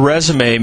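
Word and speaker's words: résumé: The word resume means summary. And by the way, resume rhymes with pay.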